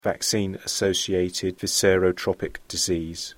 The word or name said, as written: vaccine associated viscerotropic disease